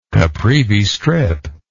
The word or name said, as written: Caprivi Strip